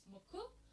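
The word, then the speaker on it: Mucc: And some of them are Moko or Muk, like the more Americanized pronunciation would be Muk, but in Japanese it's Moku.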